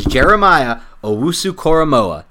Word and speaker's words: Jeremiah Owusu-Koramoah: and that is Jeremiah Owusu-Koramoah.